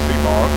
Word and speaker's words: CMOS: Their acronym is CMOS, and it's pronounced CMOS.